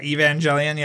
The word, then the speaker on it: Evangelion: The Evangelion? I'm saying the name right or not? Okay. – Yes. Yeah. Evangelion.